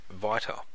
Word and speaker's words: beta: And also the letter in the Greek alphabet, as we know it, beta, apparently used to be pronounced, or by the original Greek language, was pronounced vita.